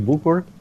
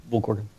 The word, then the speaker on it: Bucur: That's not the Romanian pronunciation of it, but it's as good as you'll get in English. So that's decent enough. – What is like the true pronunciation? If there's like a more complicated version, it's Sergio Bucur.